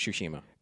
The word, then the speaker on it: Tsushima: Ghosts of Tsushima.